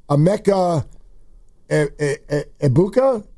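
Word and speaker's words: Emeka Egbuka: Emeka Ebuka? – Yeah, that's right.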